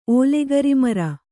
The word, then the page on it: ಓಲೆಗರಿಮರ: kannada